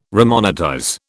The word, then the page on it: remonetize: British English